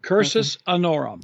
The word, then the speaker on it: cursus honorum: And this idea of cursus honorum, right? Did I say that right? – That's the Latin expression, cursus honorum.